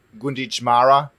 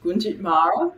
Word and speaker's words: Gunditjmara: You're a Gunditjmara woman. Do I say it correctly? – You did, Gunditjmara, yeah.